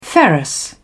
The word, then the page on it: ferrous: British English